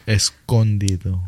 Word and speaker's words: Escondido: Wait, E-S-C-O? – Yes. – Escondido. – Escondido. – There you go. – a resident Mexican. – It's got the long O sound.